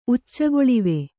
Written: ಉಚ್ಚಗೊಳಿವೆ

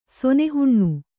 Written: ಸೊನೆಹುಣ್ಣು

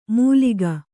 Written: ಮೂಲಿಕ

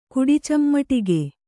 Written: ಕುಡಿಚಮ್ಮಟಿಗೆ

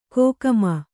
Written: ಕೋಕಮ

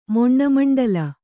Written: ಮೊಣ್ಣಮಂಡಲ

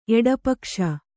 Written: ಎಡಪಕ್ಷ

